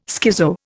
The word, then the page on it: schizo: Indian